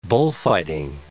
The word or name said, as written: bullfighting